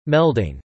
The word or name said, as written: melding